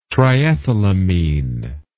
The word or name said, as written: triethylamine